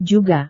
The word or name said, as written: juga